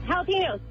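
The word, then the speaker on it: jalapeño: Jalapenos. – Leanne, on the board very soon. Jalapeno, that's a silent J, but it works.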